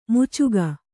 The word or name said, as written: ಮೋಚಿಕೆ